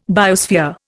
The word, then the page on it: biosphere: Indian